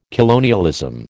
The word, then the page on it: colonialism: American English